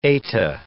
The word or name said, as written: eta